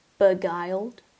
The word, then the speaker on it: beguile: I excuse myself to leave for the bathroom, just to make sure my target is fully beguiled. Beguiled. Begwilled. How to pronounce. What? How do I say that? Hello, please help me out. Oh, I put my volume down. – Beguile. – Oh, beguile. Okay.